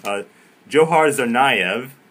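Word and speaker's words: Dzhokhar Tsarnaev: I had to, you know, rehearse the pronunciation of this, like, so many times before the show. Johar Tsarnaev got that right.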